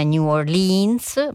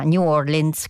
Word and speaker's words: New Orleans: Sono andata a cercare prima di andare in onda la pronuncia esatta, quindi non è New Orleans ma New Orleans, così si dovrebbe pronunciare, e proveremo insomma a pronunciarlo sempre correttamente.